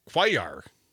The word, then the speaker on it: Cuellar: The guy's under indictment, the guy in Texas, C-U-E-L-L-A-R, Cuellar, it's pronounced oddly, who was exiled for his position, well, that and the indictment.